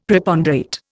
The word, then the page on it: Preponderate: Indian